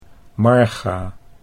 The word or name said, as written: Marga